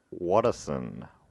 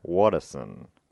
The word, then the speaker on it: Watterson: Anyway, this week's topic is their creator, Bill Watterson. Watterson.